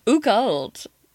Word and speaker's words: occult: Occult. Is it? Wait, how do you pronounce it? – Occult. – Occult. – Like the occult, yeah. – O-C-C-U-L. – U-L-T.